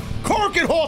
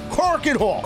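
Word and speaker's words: Korakuen Hall: Say it properly, sir! Corken Hall! It's the first place I've ever wrestled in Japan. Corken Hall!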